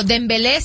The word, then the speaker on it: Dembélé: Pero, por ejemplo, Dembélé sí tiene acento en la última, ¿eh?